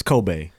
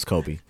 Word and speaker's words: Kobe: First off, we said his name wrong for years. It's Kobe, but it's Kobe.